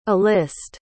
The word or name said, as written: A-list